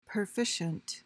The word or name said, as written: perficient